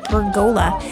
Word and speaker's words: pergola: Yep, that's how you pronounce it, pergola.